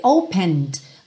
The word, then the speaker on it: opened: Percebe que esse opened, você quase não ouve o D, porque o E já não é pronunciado, então opened myself, opened myself, fica muito sutil.